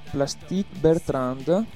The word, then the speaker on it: Plastic Bertrand: Da Mauro Plastic Bertrand, pronuncia giusta? – Sì, perfetta.